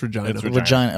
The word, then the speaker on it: Regina: It's pronounced Regina.